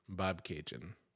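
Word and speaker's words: Bobcaygeon: They say it's pronounced like Bab... Cajun, Bob Cajun.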